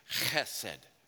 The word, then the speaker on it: chesed: Now, so key in the Hebrew there, the, the, the phrase steadfast love, there's no ch sound in Hebrew, so it's chesed love. It's a guttural. CH is pronounced chet.